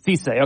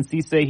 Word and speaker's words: Cisse: How do you say Sissy's last name? – Cisse. – Okay, Brandon Cisse.